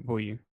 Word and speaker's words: Bouye: Is that how you pronounce the name? Bayou? – AJ Bouye. – Bouye. That was close.